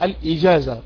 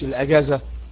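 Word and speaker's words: الإجازة: طيب نيجي بقى للايه، للاجازات، وهي النطق الصحيح الاجازة مش الاجازة، حتى الاجازة السنوية، الاجازة الشهرية، الاسبوعية، اسمها الاجازة.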